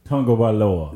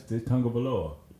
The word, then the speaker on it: Tagovailoa: Tagovailoa. – Yeah, that don't even sound right. – That's Tagovailoa.